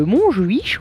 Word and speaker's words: Montjuïc: On commence avec la date du 7 juin 1896 à Barcelone, hein, vu que Pierre était parti sur l'accent espagnol, en pleine répression anti-anarchiste, où des centaines de personnes sont soumises à la torture dans la prison de Montjuïc, on va le prononcer comme ça.